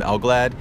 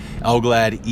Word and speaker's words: Elgallad: You can find me online, just R-E-L-E-N-T-L-E-S-S, and then F-O-R. And then Success, S-U-C-C-E-S-S. Or just under my name, Ibrahim Elglad. Either one of those. Ibrahim is spelled I-B-R-A-H-E-E-M, as in Marine. And then Elglad, E-L-G-A-L-L-A-D, as in Delta.